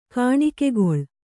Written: ಕಾಣಿಕೆಗೊಳ್

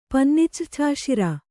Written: ಪನ್ನಿಚ್ರ್ಛಾಶಿರ

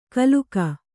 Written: ಗಿಲಕಿ

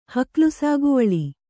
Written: ಹಕ್ಲುಸಾಗುವಳಿ

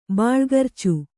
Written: ಬಾಳ್ಗರ್ಚು

